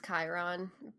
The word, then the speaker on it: Chiron: It was Chiron because my teacher knew.